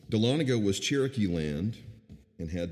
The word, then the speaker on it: Dahlonega: But she tells the story, I always mess up this, I even looked up the pronunciation, so I'll do the best I can. Dahlonega, there it is.